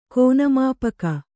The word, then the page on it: ಕೋನಮಾಪಕ: kōnamāpaka